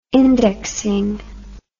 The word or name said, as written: indexing